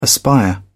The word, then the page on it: aspire: British English